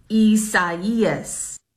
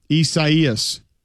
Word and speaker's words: Isaias: Isaias. – It sounds like a Nelly song. – Pretty much, yeah. – Isaias. – Got it. Thanks. Isaias.